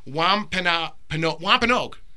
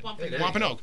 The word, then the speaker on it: Wampanoag: Yeah, I'm going to butcher. Wampanoag. That's how it's said. – Wampanoag.